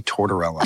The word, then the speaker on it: Tortorella: Tortorella. That's how you say it, right? – Nicolo Luigi Tortorella.